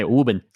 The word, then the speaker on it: Woburn: I got to say Woburn. – Yeah, Woburn. That's right. That's a proper Massachusetts pronunciation.